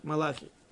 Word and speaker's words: Малахи: Но Мелахим, не царей, книги Мала, Малахи, пророка Малах, Малахи. Вот, правильно ударение поставил, все стало сразу на место.